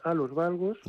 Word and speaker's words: Hallux Valgus: O Hallux Valgus, ¿no? Creo que es el nombre, si no lo he pronunciado mal. – Sí, Hallux Valgus, sí.